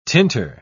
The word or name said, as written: tinter